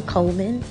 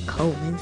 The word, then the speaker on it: Coleman: Oriana Coleman on Facebook. And I'm going to spell my name, because a lot of people spell it wrong. It's O-R-I-O-N-N-A Coleman, C-O-L-E-M-A-N.